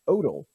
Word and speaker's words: Odle: So we welcome for the first time to the program Mr. Dean Odle.